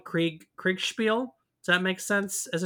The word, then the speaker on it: Kriegsspiel: Yeah, Kriegsspiel.